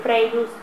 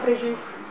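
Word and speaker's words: Fréjus: a quello del Frejus o Frejus, scegliete voi la pronuncia.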